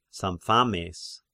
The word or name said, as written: Sampsames